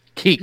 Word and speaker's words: Kuechly: You don't pronounce the you. Chat, back me up on this. Come on, man. Luke Kuechly. There's no you there.